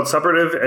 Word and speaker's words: suppurative: So let's talk about suppurative and non-suppurative. Did I say that right? Supportive? – Suppurative. – Suppurative. – You're good.